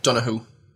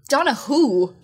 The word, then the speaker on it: Donoghue: I always think it's funny how Americans pronounce Donoghue. – How would you say it? – Donoghue. – Donoghue. – Donoghue, yeah.